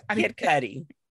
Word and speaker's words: Cudi: Kid Cudi.